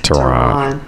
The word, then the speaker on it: Taron: Taron Egerton. – Egerton. – He. – Taron. – T-A-R-O-N.